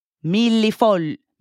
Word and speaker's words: millefoglie: In French, it would be millefeuille. However, in Italian, millefoglie. Millefoglie.